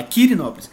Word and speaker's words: Quirinópolis: Pi, com P. Agora o meu é com Q. – Quirinópolis. ah, bom, eu já ia, tava achando que eu tava falando errado aqui. – Não, é Quirinópolis. E aí, então, pra mim foi bastante inspirador também.